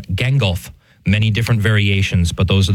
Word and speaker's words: Gangolf: And yes, it's spelled very much like the wizard from The Lord of the Rings, but this is G-A-N-G-O-L-F. So G-A-N and then golf, Gengulf.